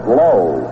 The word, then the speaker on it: Lau: They have the pronunciation in the American League book. I've always maintained as not reliable as low.